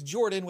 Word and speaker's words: Jorphdan: My name is Jordan with a silent PH in the middle.